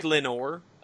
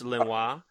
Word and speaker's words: Lenoir: Looks like Lenore. But that's what I have. – French, it's Lenoir.